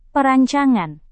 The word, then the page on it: perancangan: Indonesian